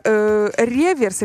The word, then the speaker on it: реверс: Ну правильно, потому что аверс — это лицевая сторона монет, а реверс, именно так правильно произносить, оборотная сторона монеты, ну или медали.